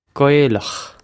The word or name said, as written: Gaelach